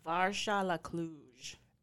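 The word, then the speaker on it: varză à la Cluj: But the national dish of Romania, the authentic Transylvanian specialty, how do you pronounce it? – Varsha la Cluj.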